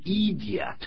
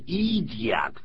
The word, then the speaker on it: idiot: 这个字变成 idiot, 不是这样念 idiot, 是这样念的。 – Idiot. Idiot. 第一个 I 是念作 I 短音。第二个是 E 是长音。